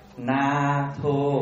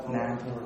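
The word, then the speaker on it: Nātho: Na Tho là viết cái chữ N A dấu ngang trên đầu, Thô là T H O, đọc là Na Tho. Na Tho.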